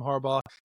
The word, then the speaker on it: Harbaugh: Jim Harbaugh to the Los Angeles Chargers. I said it right. Jim Harbaugh to Los Angeles Chargers.